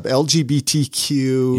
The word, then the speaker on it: LGBTQ: LGBTQ.